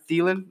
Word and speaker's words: Thielen: Number 10, I'm going to have Adam Thielen.